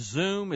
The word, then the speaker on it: Xoom: Is it pronounced Zoom? It's spelled XOOM. – Yes.